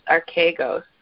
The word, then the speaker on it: archegos: The word captain in the Greek is spelled A-R-C-H-E-G-O-S, archegos.